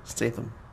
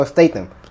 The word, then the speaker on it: Statham: Fucking, how you say it? Staten? – I just love to hear you just not able to be able to pronounce it. It wasn't right. – No, not at all. – What the fuck was it? – Statham. – Oh, Statham. Yeah.